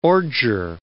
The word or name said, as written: ordure